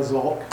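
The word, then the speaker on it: Zolck: My name's Dennis John Zolk. Last name's Zolk, Z like in zebra, O-L-C-K.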